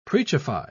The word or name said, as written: preachify